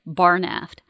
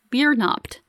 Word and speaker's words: Biernaft: It's spelled Barnaft, but it's not pronounced the way you're saying it, Barnaft. It's pronounced Biernaft.